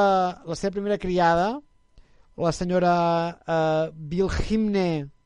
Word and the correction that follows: Wilhelmina: no sé si es pronuncio bé. – Wilhelmina, Wilhelmina. – Uh, ah, sí, sí, Wilhelmina, disculpa.